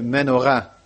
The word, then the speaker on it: menorah: Maar dit is geen Hanukkia, dit is een menorah. Of eigenlijk moet je menorah zeggen in het Hebreeuws. Dan leggen ze de klemtoon net wat anders.